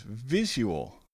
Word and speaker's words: visual: Our first word is visual. Visual. Visual has a short I, a Z sound where the S would go, a long U, and then a schwa sound.